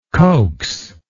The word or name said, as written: coaxingly